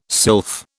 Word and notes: sylph: British English

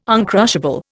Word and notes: uncrushable: Indian

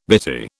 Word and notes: bitty: British English

